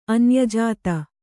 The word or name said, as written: ಅನ್ಯಜಾತ